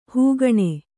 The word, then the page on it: ಹಗಣ: Kannada